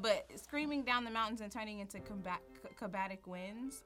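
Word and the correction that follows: katabatic: Catabatic winds, sorry. I keep saying it wrong.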